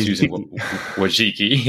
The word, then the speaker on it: Wojcicki: Susan Wojcicki, yes.